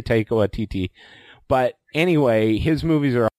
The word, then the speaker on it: Taika Waititi: And then the other thing I'll pitch, I'm a big Taiki Waititi. My daughter's always make fun of the way I say, I think it's supposed to be Taika Waititi.